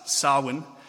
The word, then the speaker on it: Samhain: Again, they pronounce words different than us. Samhain, which occurred on the same date as what we do Halloween now.